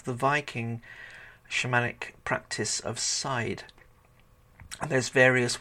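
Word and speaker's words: seiðr: It's like a side of meat, side, as in not the front, not the back, but the side.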